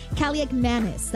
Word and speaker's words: Kaliakmanis: Washington losing quarterback Marcus Mariota to a knee injury, being replaced, yes, by Ethan Kaliakmanis. That is correct. That is how you say it.